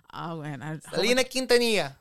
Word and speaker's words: Quintanilla: So Selena is based on the true story of Selena Quintanilla.